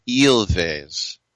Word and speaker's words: Ilves: By the way, on the Twitter, at Rick Tittle, Robert in Michigan, who's Finnish, he said Ilves is pronounced Ilves. He said it's a peculiar language for sure. No silent letters, no inflection.